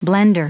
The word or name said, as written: blender